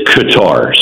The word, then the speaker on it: Qatar: Is it Qatar? – I have heard both. I pronounce it as Qatar, so close enough.